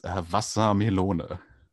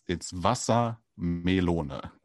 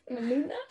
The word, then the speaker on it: Wassermelone: It's Wassermelone. – Meluna. – Right. It's less of this ooh and more of a oh thing, like at the end of it, like melona, like kind of as if you're surprised by that part of the word, like Wassermelone.